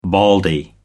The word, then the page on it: baldy: American English